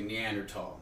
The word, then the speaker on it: Neanderthal: The proper pronunciation is actually Neanderthal. Not many people know this. – Yes. Neanderthal. – Tall. – I always got to point that out. – Yeah, there's no H at the end.